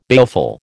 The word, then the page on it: baleful: American English